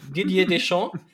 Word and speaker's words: Didier Deschamps: Didier Deschamps.